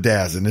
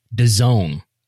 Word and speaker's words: DAZN: So what's the spelling? Tell everybody what the spelling is. D A Z N. The zone. – The zone. Is how you pronounce that brand.